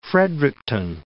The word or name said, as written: Fredericton